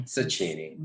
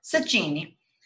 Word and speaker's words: Cecchini: Dario Cecchini. Cecchini? How do you say this? Guys, help me. I am Italian. – Cecchini. – Embarrassing. – I'll go Cecchini.